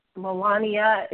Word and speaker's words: Melania: It was an alternative left e-zine that I was reading, and they were just going on a rant about the fact that Melania, is that how you pronounce the first lady's name? – Yeah.